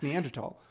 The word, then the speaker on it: Neanderthal: It's how you pronounce it. You pronounce Neanderthal.